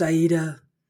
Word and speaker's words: Aida: I had just announced that it was Verdi's Ada, and immediately the phone rang. I answered it and he said, "Ms. Griffin, it's pronounced Aida. Please make note of it.